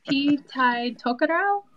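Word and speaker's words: Te Tai Tokerau: Oh, please pronounce the name of that team for us. – Oh, I was hoping you were going to bite that bullet for me. Pee Tai Tokerau? – That works for us.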